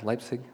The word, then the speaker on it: Leipzig: Yeah, Leipzig. It's a funny-looking word, but during the 1980s. At the height of the Cold War, Pastor Christian Führer began a Monday night prayer service at his church in Leipzig, East Germany.